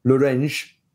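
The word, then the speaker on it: Llorente: So I'm now referring him as Firpo, and it's not Lorente anymore, it's Lorenge.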